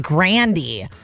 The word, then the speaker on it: Grande: Because Ariana Grande just revealed her last name was supposed to be pronounced Grandy.